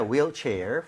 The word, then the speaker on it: wheelchair: H가 없는 느낌으로 발음할 때 wheel chair이라고 하는 게 맞습니다.